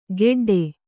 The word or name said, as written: ಗೆಡ್ಡೆ